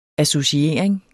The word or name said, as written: associering